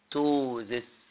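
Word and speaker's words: ptosis: بنسميها في الطب كلمة جميلة توزس. خلي بالكم البي سايلنت ما بنتنطقش، اوعى تنطقها. يبقى دي اول حاجة توز.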